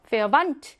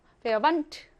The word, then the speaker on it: Verwandt: Verwandt. 네 테는요, 여러분. 드트 이렇게 발음하지 않고요. 합쳐가지고 트한 번만 발음해요. Verwandt.